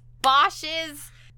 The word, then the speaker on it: Bosch: Hieronymus Bocht. Oh my god, I really botched that. Hieronymus Bosch's.